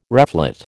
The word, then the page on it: reflate: American English